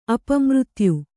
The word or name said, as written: ಅಪಮೃತ್ಯು